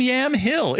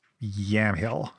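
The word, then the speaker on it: Yamhill: Probably given the Oregonian accent, it's Yam Hill. – Yam Hill. – Yeah. – Yamil. Yeah. Oh, yeah, yeah. If you go there, they're probably like, no one pronounces the H.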